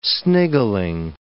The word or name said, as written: sniggling